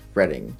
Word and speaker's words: Reading: The Blues Cruise 50K held in Reading, Pennsylvania, which is apparently pronounced Reading. Apparently I have been wrong in Monopoly for years.